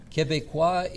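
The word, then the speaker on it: Québécois: Quebecois is the right pronunciation of Quebecers. So this is how people from Quebec call themselves.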